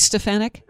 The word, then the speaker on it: Stefanik: Elise Stefanik? – Stefanik, that's how you pronounce it.